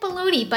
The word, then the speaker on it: bologna: It's like baloney, but how it's actually supposed to be said.